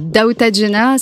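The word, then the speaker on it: Dawta Jena: Alors, Daouta Jenna, ça s'écrit D-A-W-T-A, plus loin, Jenna, J-E-N-A.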